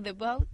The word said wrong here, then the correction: Doubt: The Bout, ¿cómo se dice, Doubt. – Eso, Doubt, eso.